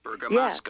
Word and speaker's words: Bergamasco: Bergamasco.